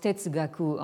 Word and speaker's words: Tetsugaku: Donc, euh, euh, ce mot se prononce Tetsugaku en, en, japonais.